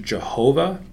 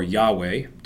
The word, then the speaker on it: Yahweh: We assign vowels to it, and it's either pronounced Jehovah or Yahweh, depending on how you pronounce it.